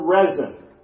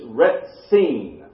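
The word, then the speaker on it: Rezin: Some pronounce the word resin. Actual Hebrew pronunciation, because the letters that is used, is Retzin, I-N, the plural ending.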